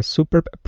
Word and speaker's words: superb: At a superb private practice in North Square. – Superb. Accent on the second syllable.